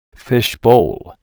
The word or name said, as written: fish bowl